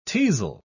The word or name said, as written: teasel